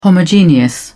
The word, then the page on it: homogeneous: British English